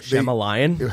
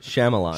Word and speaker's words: Shyamalan: Night Shyamalan movie where the... – Shyamalan? – How do you say it? – Shyamalan. – Shyamalan. Ding ding.